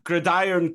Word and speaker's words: gridiron: It's gridiron crew because it's like iron brew, so you run it together.